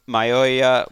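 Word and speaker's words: Mayowa: Benson Mayoya. I never know how to say his name. He's better than Furl. – You got it.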